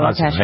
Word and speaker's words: Waukesha: All right, speaking of trials, here's the result of the trial in Waukesha? I think that's how you pronounce it. – Yeah, Waukesha.